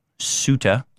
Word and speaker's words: Ceuta: I figured out how to pronounce it. Ceuta, I believe.